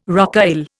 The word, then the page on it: rocaille: Indian